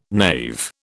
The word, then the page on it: knave: British English